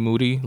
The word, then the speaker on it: Moody: Um, G Monetti of the I Am Rapaport Stereo podcast, G aka G Moody, last name rhymes with duty and also Scott Adams,